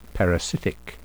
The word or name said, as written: parasitic